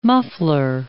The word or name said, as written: muffler